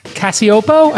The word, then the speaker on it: Cacioppo: I thought I would bring Christina Cacioppo.